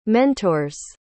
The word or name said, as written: mentor's